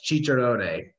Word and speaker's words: Cicerone: I believe it's pronounced Cicerone, but Cicerone.